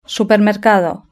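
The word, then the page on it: supermercado: Spanish (Spain)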